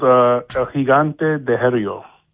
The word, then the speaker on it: El Gigante de Hierro: This one's called El Gigante de Herrio. – Okay. How do you spell that? – E-L space L-E-L. G-I-G-A-N-T-E space D-E space H-I-E-R-R-O.